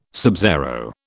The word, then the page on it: subzero: British English